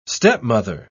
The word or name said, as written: stepmother